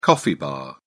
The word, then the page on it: coffee bar: British English